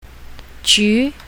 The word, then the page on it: ju2: mandarin